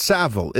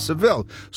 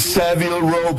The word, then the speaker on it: Savile: There's a proper way, because the way that it's spelled, there's one L, so it's Savile. If it's Seville, as you had suggested incorrectly, we might add, it's Seville.